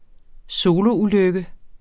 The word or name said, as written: soloulykke